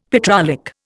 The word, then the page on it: petrolic: Indian